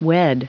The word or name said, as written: wed